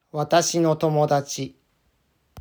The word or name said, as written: Watashi no Tomodachi